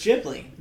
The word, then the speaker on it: Ghibli: Ghibli or Ghibli? – So, I used to say Ghibli. – Yeah, it's Ghibli. – Apparently, it's Ghibli. Apparently, it's a soft J.